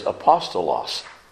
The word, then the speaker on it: apostolos: And the Greek word is apostolos.